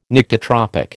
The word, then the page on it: Nyctitropic: American English